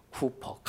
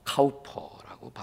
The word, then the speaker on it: 카우퍼: 윌리엄 쿠퍼, 카우퍼라고 발음하기도 하는데, 네.